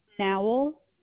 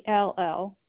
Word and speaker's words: Nowell: Nowell, Nowell. – You said Mowell. – N is in Nancy.